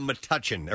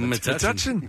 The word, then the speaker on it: Metuchen: By the way, it's called Metuchen, or that's how you pronounce it. That's how you pronounce it? – Yeah, Metuchen.